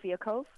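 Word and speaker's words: Fialkov: And it's being written by your buddy, Josh, is it Fiekov? How do you pronounce his last name? – Josh Fiekov. – Fiekov, okay.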